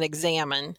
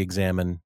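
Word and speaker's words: examen: it's spelled differently than you think. It's E-X-A-M-E-N. It's not I-N-E. So if you want to study examine that Cindy's talked about, it's so important.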